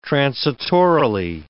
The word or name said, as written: transitorily